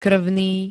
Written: krvný

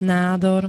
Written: nádor

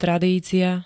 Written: tradícia

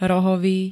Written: rohovy

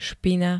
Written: špina

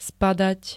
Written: spadať

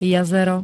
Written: jazero

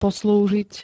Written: poslúžiť

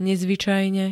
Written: nezvycajne

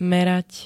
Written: merať

